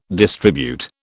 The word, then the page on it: distribute: British English